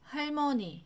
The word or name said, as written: 할머니